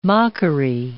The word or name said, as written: mockery